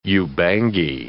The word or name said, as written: Ubangi